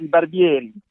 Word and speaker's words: Barbieri: Bruno Barbieri, in Italian or French, it's Barbieri.